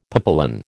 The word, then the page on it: papillon: American English